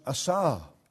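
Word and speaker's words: asah (עשה): Another word that's used here in these early verses of Genesis for creation is pronounced asah.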